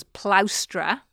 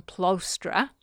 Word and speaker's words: plaustra: When an ex-consul called Mestrius Florus called his attention to the fact that the proper pronunciation was plaustra rather than plostra, all right, that's the word for a wagon.